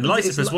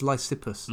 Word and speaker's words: Lysippus: It is quite tricky, but I think with these names you've got to put the emphasis at the right point. I think it's Lysippus.